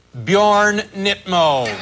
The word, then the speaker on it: Bjorn Nittmo: Guest number 4, Bjorn Nittmo. Bjorn Nittmo. Phonetically, of course, that's Bjorn. There you have your Bjorn. – There's your Bjorn. – Nit. There you have your Nit. Mo.